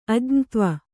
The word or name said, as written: ಅಜ್ಞತ್ವ